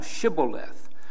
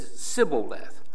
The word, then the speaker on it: Shibboleth: And if he said nay, then said they unto him, "Say now Shibboleth." And he said Sibboleth, but he could not frame to pronounce it right.